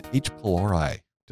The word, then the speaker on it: H. pylori: And H. pylori. Did I pronounce that correctly? Yeah, you pronounced that pretty I, good.